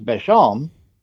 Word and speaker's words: Beauchamp: That's his Beauchamp, which is pronounced Beauchamp.